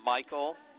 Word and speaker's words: Michael: Michael. – Can you spell it for me, please? – M-I-C-H-A-E-L.